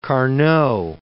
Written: Carnot

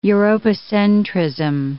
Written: Europocentrism

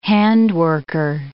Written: handworker

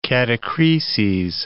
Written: catachreses

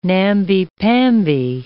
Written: namby-pamby

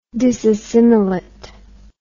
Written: disassimilation

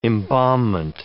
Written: embalmment

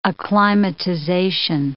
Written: acclimatization

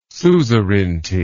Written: suzerainty

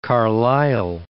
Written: Carlisle

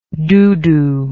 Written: doodoo

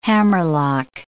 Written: hammerlock

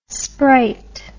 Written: spright

